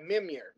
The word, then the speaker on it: Mimir: So we know that something can break Mimir. I was saying it right.